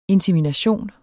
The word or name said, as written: INSEMINATIONER